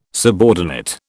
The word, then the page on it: subordinate: British English